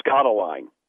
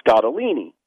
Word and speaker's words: Scottoline: And my mother-in-law kept pronouncing her name Scottoline. – And well, that happens. – About the fourth time she said it, I said her name is pronounced Scottolini.